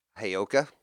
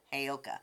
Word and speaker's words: Heyoka: Heyoka. – Heyoka.